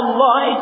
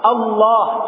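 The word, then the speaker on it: Allah (الله): Lafzul jalalah Allah itu mesti tebal. Allah, Allah, Allah, dilatih. Allah, jangan Allah.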